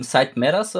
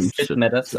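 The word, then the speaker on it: Syd Matters: Side Matters oder wie wird das äh, Sid, Nee, Sid, das ist ein Sid Matters, okay.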